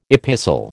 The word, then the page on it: epistle: American English